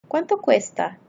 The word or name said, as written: cuánto cuesta